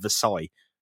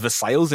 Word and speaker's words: Versailles: Like Versailles, there's a town called Versailles, which is Versailles in America, and it's not, it's Versailles.